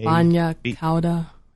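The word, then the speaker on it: Bagna cauda: Bagna Cotta? Cotta. – Yep. – That's it. – I don't even know how to pronounce that, but yeah. – B-A-G-A. – Bagna Cotta. Is that it? – Yeah, C-A-U-D-A.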